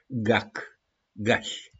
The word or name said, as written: GAC-GAȘI